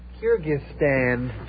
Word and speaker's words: Kyrgyzstan: Kyrgyzstan. – Kyrgyzstan? – That's the best I can spell. K-Y-R-G-Y-S-T-A-N. And... Oh, no. No, that's only if the operator does it, not me. Kyrgyzstan. Kyrgyzstan. Kyrgyzstan. It's not going to go off again, is it? K-Y-R-G-Y-Z-S-T-A-N.